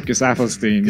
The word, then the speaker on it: Gesaffelstein: Gesaffelstein, yeah.